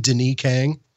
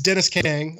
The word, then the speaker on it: Denis Kang: I want to call him Denis Kang because his name is spelled Denis. – Right, like yes, French. I understand. – It's Dennis Kang. I want to call him Denis Kang for some reason.